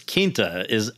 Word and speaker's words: Quinta: Hi, Quinta.